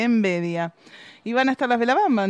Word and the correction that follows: Velabanda: entonces? – Velabanda. – Velabanda. No lo puedo pronunciar.